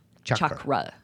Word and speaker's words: chakra: So I've been saying, I have to practice saying this word because for so long we learned this word with the sh, the chakras. And it's not pronounced that way. It's pronounced cha. – Chakra.